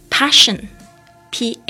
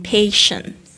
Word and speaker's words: passion: No one can steal，呃，没有人可以偷取 execution execution 非常棒的字，它是从这个 execute 这个动词来的，e x e c u t e 变成名词，变成 e x e c u t i o n，execution or passion，p a s s i o n，passion 就是激情的意思，execution or passion，大家要特别注意一下这个 passion，不要念成 patient。